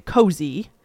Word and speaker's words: Koze: And she had always had this brand called, I think she pronounced it Cozy, but it was spelled K-O-Z-E.